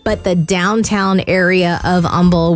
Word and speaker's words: Humble: It's got an H, but everyone says Humble, Texas. – Yep, Humble. – The H is silent.